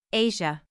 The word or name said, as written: Asia